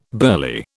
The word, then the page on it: burly: British English